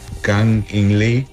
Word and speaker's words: Kang In Lee: Ah, espera, a ver si me recuerdo pronunciar su nombre correcto. Se llama Kang In Lee.